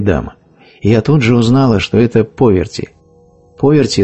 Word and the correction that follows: Парвати: Не Поверти, а Парвати.